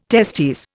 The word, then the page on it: testes: Indian